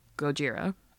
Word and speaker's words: Gojira: The ra suffix follows a precedent set by Godzilla, which has the Japanese pronunciation of Gojira, uh-huh.